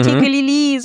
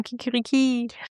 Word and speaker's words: kikiriki: Nicht kikilili, sondern kikiriki.